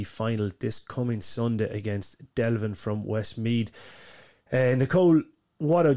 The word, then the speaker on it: Delvin: I must apologise because I think I stuttered in one of these interviews across calling it Devlin. I don't know where I got lost in my pronunciation, but Delvin from North Westmead are taking on the Cavan and Ulster champions.